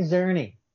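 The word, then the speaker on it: Czerny: Harry Sarzini. Once again, I don't know how to... I should have really bothered to figure out how to pronounce people's weird names. – Henry Zerny. – Yeah. Thank you.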